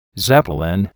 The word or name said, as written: zeppelin